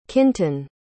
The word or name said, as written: kynton